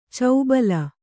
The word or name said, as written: ಚೌಬಲ